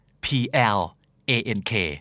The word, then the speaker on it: plank: นะเริ่มต้นที่คู่นี้ฮะถ้าเกิดออกเสียงตัว R มันจะหมายถึงการแกล้งหรือว่าการเล่นตลกแต่ถ้าสมมุติเกิดออกเสียงเป็นตัว L จะเป็นท่าออกกำลังกายที่ไม่ต้องเคลื่อนไหวแต่เหนื่อยแล้วก็เมื่อยหน้าท้องมากๆคำคู่นี้คือ prank กับ plank p r a n k prank p l a n k plank แบบ planking